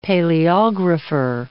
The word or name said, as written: paleographer